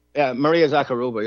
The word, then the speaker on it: Zakharova: Is that how you say it, Zakharova? The Kremlin. – Maria Zakharova. Yeah.